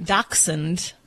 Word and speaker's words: dachshund: Well, I mean, dachshund is like the appropriate pronunciation.